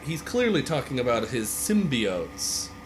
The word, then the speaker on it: symbiote: It's symbiotes. – Symbiotes, it's symbiotes. Sim. It's sim like the game Sims.